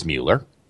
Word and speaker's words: Mueller: Now, a name from Google, and I'll keep this quick, but worth for people to note, a name that's easy to pronounce over at Google is Mueller. rhymes with Bueller, for those who missed that part.